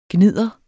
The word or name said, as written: gnidder